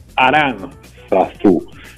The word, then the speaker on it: Aranzazú: Digo, lo pronuncio así porque se acentuó en esta segunda Nuestra Señora de Aranzazú.